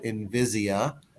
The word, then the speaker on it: Invisia: One of the companies that I would encourage people to go look at specifically is a company called Invisia, and it's spelled kind of like invisible. It's I N V I S I A, Invisia.